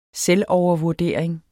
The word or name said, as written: selvovervurdering